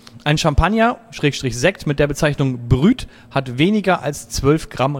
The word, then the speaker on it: brut: Ja, pass auf, es heißt nicht Brü. – Oh. – Nein, es heißt Brüt.